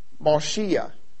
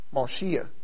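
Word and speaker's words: Moshiach (משיח): The term anointed, which in Hebrew is a little bit different than the other Hebrew word, it's Moshiach. It's spelled M-A-S-H-I-Y-A-C-H. M-A-S-H-I-Y-A-C-H. Moshiach.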